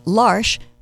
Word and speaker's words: L'Arche: L'Arche, first of all, the way you pronounce it, it rhymes with marsh.